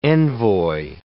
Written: envoi

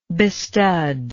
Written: bestud

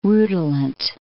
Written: rutilant

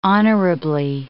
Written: honorably

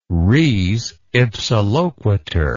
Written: res ipsa loquitur

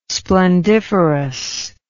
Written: splendiferous

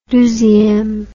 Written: deuxieme

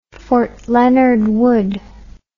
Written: fort leonard wood

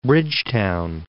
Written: Bridgetown

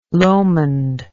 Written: Lomond

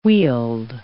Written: weald

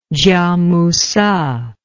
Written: kiamusze

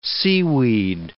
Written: seaweed

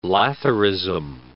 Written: lathyrism

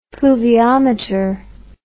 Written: fluviometer

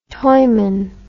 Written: toyman

